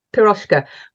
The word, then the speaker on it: Piroshka: Poroshka? What is that? – Yeah. Poroshka.